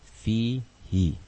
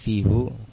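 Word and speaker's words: Fihi (فيه): Fihi. Fihi. Kenapa tidak fihu? Karena itu berat untuk diucapkan oleh Arab, sehingga yang mudah, yang ringan adalah dengan mengkasrohkan dhamir hunya, sehingga dibaca fihi.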